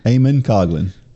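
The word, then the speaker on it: Eamonn Coghlan: Eamon Coughlin was, he's from Ireland. – Is it Eamon? – Eamon Coughlin. Yeah. – Hmm. – That's the way you say it.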